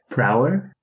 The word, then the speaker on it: Prower: Prower? – Yeah. How do you say it? – Prower.